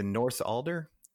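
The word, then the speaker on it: Northuldra: is it the North Alder? Am I pronouncing that right? – That sounds right to me.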